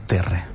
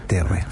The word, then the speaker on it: Terrae: En general, las universidades, lo mismo la Universidad Finis Terrae o Terre, siempre me he tenido la duda, ¿cómo se dice bien? ¿Terrae o Terre? – Los puristas te dirían que es Terre. – Terre, perfecto. Terre.